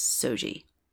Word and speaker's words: SOGI: So they use the acronym SOGI, which they pronounce SOGI.